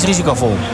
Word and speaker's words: risicovol: Tussen 10 en 14 is het het risicost vol. Zeg ik het goed, Nederlands? Het meest risicovol.